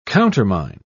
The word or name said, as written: countermine